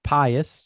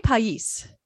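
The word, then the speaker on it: País: So pa- pious. You say pious? – I say pais.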